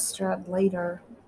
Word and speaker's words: Stradlater: I thought it was Stradlater. I don't know how you say it. – I was reading Stradler and then I saw there was a T in there. It's like Stradlater, I guess.